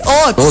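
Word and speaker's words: haute: Es que es H-A-U-T. Ah, ¿eso no es francés? Hot.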